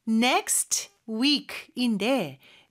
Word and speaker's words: next week: Next week인데 next의 요 T 발음은요. 사실 발음이 안 되는 경우가 많아요. 모음 이 아니라 자음 소리 사이에 낀 T나 D는 생략이 되는 경우가 있거든요. 그래서 여기서도 next week, next week 이렇게 발음할 수 있습니다.